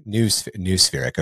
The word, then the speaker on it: noospheric: Noospheric? Is that how we pronounce that? – Noospheric.